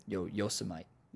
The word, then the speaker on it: Yosemite: You coming through Yosemite? Is that how you say it? Did I say that right? – Yosemite.